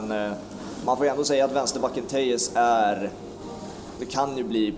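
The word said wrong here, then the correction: Telles: Nej, Tellis, det, vi, vi, vi, googlade upp det korrekta uttalet.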